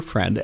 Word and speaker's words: Freund: Sadie Friend is here listening to Friend, spelled like the German word for friend, F-R-E-U-N-D. But based on your grandparents' preference, Friend is the pronunciation.